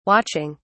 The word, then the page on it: watching: British English